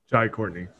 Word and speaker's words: Jai Courtney: We also get introduced to Captain Boomerang, played by Jay Courtney. Is that how I say his name? Jai Courtney.